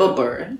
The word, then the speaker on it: Buber: There's a guy named Martin Buber. – Buber? – Yeah. – Can you spell that for me? – B-U-B-E-R. – Okay. – I'm probably pronouncing it in Buber.